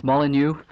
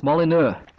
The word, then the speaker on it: Molyneux: Yeah, the first one was Molyneux. I believe you pronounced it Molyneux. That's an A in the name. And mine's an English pronunciation of a French name, and it's Molyneux. Or in France, it's Molyneux.